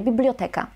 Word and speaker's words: biblioteka: Bibliotekę akcentujemy na przedostatniej sylabie, biblioteka. Dlaczego? Dlatego, że zarówno w łacinie, jak i w grece, a to słowo pochodzi z greki, to słowo było akcentowane na przedostatniej sylabie, więc nie ma powodu, żeby teraz tę sylabę w polszczyźnie przesuwać.